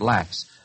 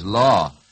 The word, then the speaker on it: Laux: Or as she says, her name's spelled L-A-U-X, and some people pronounce it Lax. Well, I wouldn't be so lax, Marie Louise, as to pronounce it Lax, since you've told me the right way to say it is Law.